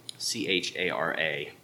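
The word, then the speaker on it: chara (χαρά): The Greek word used in Ephesians there is C H A R A, but it's pronounced chara.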